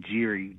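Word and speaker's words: Gerry: We pronounce his name Geary, gerrymandering.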